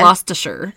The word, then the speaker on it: Gloucestershire: And I know that's how you pronounce that because I wrote it phonetically because it's a very big word. – Gloucestershire. – Gloucestershire.